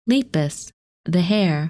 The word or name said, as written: Lepus